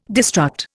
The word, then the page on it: destruct: Indian